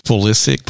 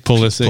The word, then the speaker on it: Pulisic: I don't know. In Europe, it might be Pulisic.